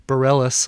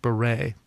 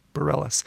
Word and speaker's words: Bareilles: And doing some checking around the internet, looks like it is pronounced Bareilles, although from her background you'd think it was pronounced Beret, but nope, Bareilles, and her cover of A-ha's Take On Me.